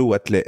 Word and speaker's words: Watelet: Bruno Watley.